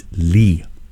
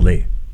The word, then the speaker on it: Leigh: L-E-I-G-H is Lee. – Ooh. – It's not Lay. – Mm. – Although it sounds like a Lay, it is pronounced as a Lee.